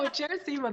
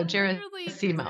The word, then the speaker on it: Gerasimo: No, Gerasimo, that's right. I always tell people it's like Seymour, but with a Jera on the front. So Gerasimo.